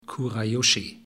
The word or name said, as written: Kurayoshi